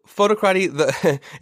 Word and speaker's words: Photocrati: Yeah, so Photocrati, it's funny. Photocrati, it's spelled photo, C-R-A-T-I.